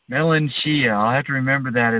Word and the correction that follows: melancholia: Melanchia. Cholia. – Cholia. Melancholia.